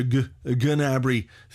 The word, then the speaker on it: Gnabry: And they definitely don't pronounce the G. There's a sort of a hint of it. It's like Gnabry. But definitely no Gnabry.